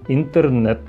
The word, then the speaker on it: Интернет: Интернет, произносим «э»,